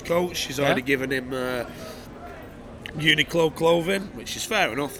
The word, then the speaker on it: Uniqlo: I just saw Federer's coach wearing a Barilla hat and some Uniqlo. – Is that how you pronounce it? Uniqlo. – Uniqlo, yeah.